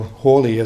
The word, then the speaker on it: Halley: And I like to date the beginning of planetary science at Oxford from the time of Edmund Halley, or Hawley as he would have pronounced it himself, who was very much an Oxford man.